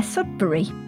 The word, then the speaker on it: Sudbery: And you can find that at Clare Sudbery, which is probably not spelt the way that you think. There's no I in Claire, and Sudbery is spelt E-R-Y at the end, the same as surgery or carvery.